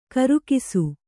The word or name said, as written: ಖರ್ಗಸ